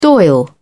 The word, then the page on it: dail: British English